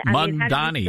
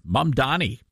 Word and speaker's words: Mamdani: How do you say his name? Mamdani? – Mamdani, yeah. Mamdani. – Yeah, that's how I say it.